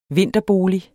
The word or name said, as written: vinterbolig